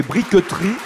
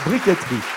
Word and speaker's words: briqueterie: On pourrait prononcer briqueterie, mais on dit communément briqueterie. Donc ça s'écrit B-R-I-Q-U-E-T-E-R-I-E, la briqueterie.